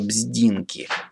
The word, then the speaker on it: Бздинки: Их строгий подбор никоим образом не позволял усомниться в благонадежности хозяина господина Венцеля Бздинки с ударением на первом слоге.